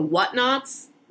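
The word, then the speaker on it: Whatnauts: It's on a network called The Whatnots. Knots spelled like N-A-U-T-S, like an astronaut.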